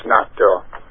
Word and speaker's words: natto: Yes, natto.